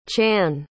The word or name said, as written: channe